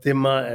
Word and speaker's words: Dhimma: In Arabic they pronounce it as Dhimma and Dhimmi, you know, I can't pronounce that particular vowel correctly, it's a very deep guttural sound. So, but in India we pronounce it as Zimma and Zimmi.